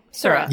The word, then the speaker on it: Sura: Anyways, but also, is it Sura? Or am I saying it wrong? Sura. – Yeah.